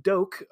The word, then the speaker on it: Doak: So a guy instead named Nathan Doak, rhymes with bloke, who looks like maybe like a three-episode sort of recurring character from Peaky Blinders.